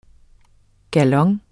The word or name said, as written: GALONERNES